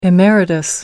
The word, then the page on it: emeritus: American English